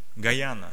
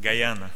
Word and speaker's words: Guyana: He comes from country in South Africa, South America, Guyana. Did I say it right? – Yeah. – Guyana.